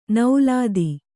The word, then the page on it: ನೌಲಾದಿ: naulādi